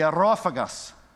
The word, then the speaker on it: Areopagus: So they took him and they brought him to, this is going to sound a little weird, but it's the only way to really pronounce it. You can't pronounce it really in English. The Areopagus, all right? The Areopagus.